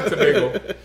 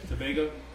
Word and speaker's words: Tobago: No, Tobago. – Tobago? – Yeah.